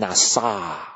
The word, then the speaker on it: nasa: 这个字啊很有意思啊，这个字跟我们那个 NASA, 这个美国这个 NASA 这个字很像，它怎么念，它念成 NASA。